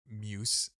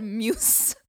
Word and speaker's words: muse: Dude, can you imagine saying the word muse about like a potential? – I'm sorry, you just pronounced it like the dessert moose. You said muse.